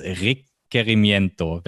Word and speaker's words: Requerimiento: Das regt Querimiento, wenn mein Schulspanisch gut genug ist, um das verständlich auszusprechen. Ja, das, das haben Sie gut ausgesprochen.